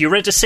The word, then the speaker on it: Eurydice: It's Eurydice.